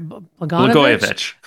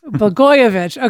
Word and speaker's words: Blagojevich: Blagojevich. – Blagojevich.